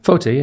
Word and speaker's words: Foteh: Foteh, yeah, it's good. – I kind of like emphasize the O. – Man, Foteh.